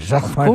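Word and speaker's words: Jacquemart-André: Jacques-Marc-André.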